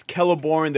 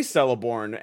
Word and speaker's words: Celeborn: I know, it's Celeborn. There are no soft C's in Middle Earth. I know that. I learned that later in life. Because I used to say Celeborn and, you know, Cirith Ungol.